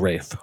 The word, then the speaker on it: Ralph: Ralph, but it's pronounced Rafe.